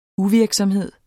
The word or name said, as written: uvirksomhed